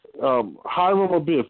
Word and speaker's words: Hiram Abiff: So now he's Hiram Abiff, that's how you say it.